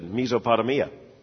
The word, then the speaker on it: Mesopotamia: And she said, why would you think that's pronounced Mesopotamia? And he said, well, mesothelioma.